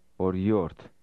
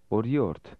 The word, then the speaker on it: օրիորդ: Repeat after him, part by part. – Yort. Yort. Or. Or. Or yort. – Say, miss. – Or yort.